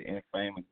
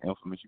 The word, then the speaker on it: Infamous: Oh, I say most people, they mispronounce it. They say Infamous, but it's Infamous.